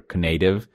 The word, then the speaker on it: Knative: I finally watched, you know, I heard the episode, I wasn't around, where you answered Tasty Meat Paul's question, what exactly is K-native or knative? Or as someone in our Slack channel has suggested, native, because the K is silent.